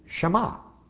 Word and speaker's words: Shema: And that's, they call it the Shema because the first word is hear, and in Hebrew the word is Shema. Shema Yisrael, Adonai Elohim, Adonai Echad. That's how they say it in Hebrew.